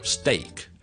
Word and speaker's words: steak: 我自己本身的理論就是我好像 SM 已經蝕了一點氣了，所以後面的 TM 我就不用噴氣噴得那麼厲害，那聽下去就是 still，比其他例子譬如我吃牛扒 steak steak steak，那我就不需要讀成 steak， OK， yeah, steak 這個要大家平時留意一下， OK，就不需要是噴氣噴得那麼厲害，